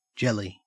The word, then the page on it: jelly: Australian English